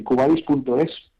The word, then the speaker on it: Qvadis: Cubadis, que se escribe una Q y luego Q-V-A-D-I-S. – Eso es. – S. – O sea, sí. Q, de la letra Q y luego Badis con V.